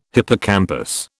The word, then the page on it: hippocampus: British English